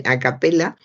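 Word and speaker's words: a cappella: Para empezar, he visto en un diario cantar a capela capela escrita con ella eh, a capella, a capella, es escrito con ella que sería, eh, se no pronunciaría o sería a capella, no se pronunciaría así porque en italiano dicen a capella,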